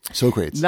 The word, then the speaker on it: Socrates: Socrates. – Socrates? I'm sorry, I didn't realize I was pronouncing it wrong.